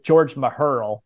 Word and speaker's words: Mecherle: The book was actually written in 1955, and it's a biography about this founder, and his name is George Meherl, but that's spelled Mecherle.